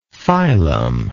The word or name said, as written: filum